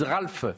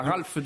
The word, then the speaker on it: rafle: Ils disent Ralph, la rafle du Veldiv.